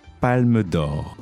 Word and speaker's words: Palme d'Or: Palme d'Or.